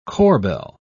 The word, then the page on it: corbeil: British English